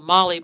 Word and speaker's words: MOLLE: Now, what he's referring to is a Molly bag. It's spelled M-O-L-L-E.